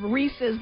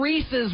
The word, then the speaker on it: Reese's: If Mr. Reese's was around right now, he would tell you how to pronounce it. – Yeah, Mr. Reese's. He's one of the other ones that says Reese's. – Reese's. – It's Reese.